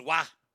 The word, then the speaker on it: Roy: He spelled his last name R-O-Y, but he was from French Quebec, eh? So he took the French pronunciation, which is Wah. R-O-Y is Wah. But not in America, it ain't, because that's my middle name. R-O-Y is Roy.